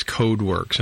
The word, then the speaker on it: Codewerks: But, and it's, the name of it is CodeWorks, and that's CodeWorks spelled with an E.